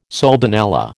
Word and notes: soldanella: American English

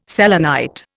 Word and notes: selenite: Indian

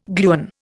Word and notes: gluon: Indian

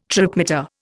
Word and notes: tripmeter: Indian

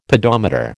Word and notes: pedometer: American English

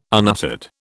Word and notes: unuttered: British English

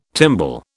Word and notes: timbal: British English

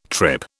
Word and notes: trip: British English

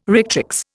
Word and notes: rectrix: Indian

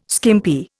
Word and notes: skimpy: Indian

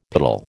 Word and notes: piddle: American English

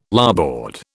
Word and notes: larboard: British English

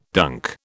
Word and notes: dunk: British English

